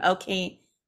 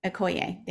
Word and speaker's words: Okoye: Okoye. – Okoye, thank you.